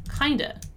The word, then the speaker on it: kind of: So half in our sentence here means kind of, which, as you already know, is pronounced kinda.